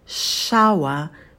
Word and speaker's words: shower: Shower, shower. На звуке «ш» у нас кончик языка идет наверх, и затем у нас триптонг «ауа», «ауа», почетче его произносим.